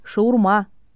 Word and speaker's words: шаурма: Наряду с этим названием в России бытует еще два варианта – «шаверма» и «шаурма» с ударением на второй слог. Но правильный вариант – «шаурма» – ударение на «а».